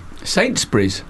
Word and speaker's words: Sainsbury's: Sainsbury's? – Yeah. I still can't say it. I get flashbacks. But how do you say it? Sainsbury's? – Sainsbury's.